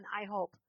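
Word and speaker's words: IHOP: God, we bless the I Hope. That's how you say it, right? Yeah, we bless I Hope.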